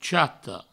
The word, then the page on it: chata: chato; provençal